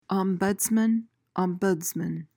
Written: ombudsman

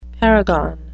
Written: paragon

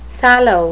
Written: sallow

